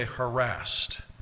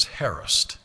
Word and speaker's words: harassed: Now, most of you would say harassed, but the proper pronunciation is harassed.